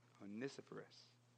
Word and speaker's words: Onesiphorus: Onesiphorus, who searched earnestly for him throughout Rome.